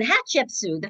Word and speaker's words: Hatshepsut: But I went to her one day and I said, you know, Anne, there was a big special on Hatshepsut the other night. And she said, who? And I said, Anne, Hatshepsut, the female pharaoh.